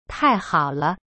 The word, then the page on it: 太好了: tai-hao-le